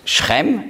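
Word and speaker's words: Schem (שכם): Sichem, auf Hebräisch spricht man aus Schem, aber es ist das Gleiche.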